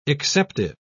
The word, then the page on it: exceptive: British English